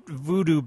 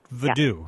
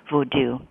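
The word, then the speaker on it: Vodou: Not voodoo, but voodoo. – Yeah. Yeah, well, that's the way you pronounce it in Haiti. And also, increasingly, scholars of religion here in the U.S. and around the world pronounce it that way, voodoo. And that's how the Library of Congress says it, too.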